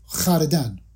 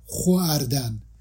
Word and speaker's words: خوردن: در نتیجه فعل خوردن رو که ما امروز خوردن تلفظ می کنیم، اگر با تلفظ قدیم بخوایم تلفظ کنیم، با این بوده خردن، خردن، نه خواردن، خردن، خردن یا خش، نه خوش. این صدای واقع مصوت بعد از این نوخه ا هست، نه او اصلا. خب ولی تلفظ در طول زمان تغییر کرده، املا در جای خودش باقی مونده.